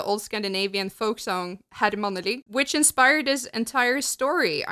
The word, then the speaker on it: Mannelig: It says here that, and I'm going to pronounce this the Norwegian way, so it's going to sound very stupid, probably mixed with English, but the head honcho of the town is called Manneli, is how you would say that in Norwegian, which is very close to Manly, if you translate it.